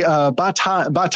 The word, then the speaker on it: Bataille: But they, Bataille, I'm saying his name, it's all Frenchy, but this guy, Bataille, there's a silent L in there.